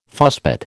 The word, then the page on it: fusspot: American English